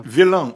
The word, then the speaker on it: Виллен: Виллен и произносится, и пишется с точностью до буквы, так, как пишется злодей.